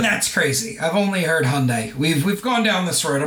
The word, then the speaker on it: Hyundai: my pronunciation of Hyundai, and obviously growing up in Australia where that is how you pronounce it. This is the first time I'd ever come across someone saying Hyundai.